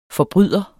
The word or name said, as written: forbryder